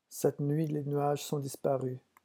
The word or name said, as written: cette nuit